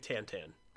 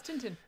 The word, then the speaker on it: Tintin: It's pronounced, I think, Tantan. – Oh. – But I say Tintin. – It's Tintin.